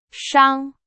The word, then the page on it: 伤: Chinese